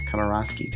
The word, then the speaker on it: Komoroske: And I am talking to the executive director from the Walt Disney Family Museum at the Presidio in San Francisco, Kirsten Komorowski.